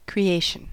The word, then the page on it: creation: American English